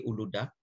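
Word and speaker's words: Uludağ: Eh, putra saya di Bursa kuliahnya di Uluda ya eh, walaupun pakai G tapi orang Turki nggak membacanya G jadi di Uluda.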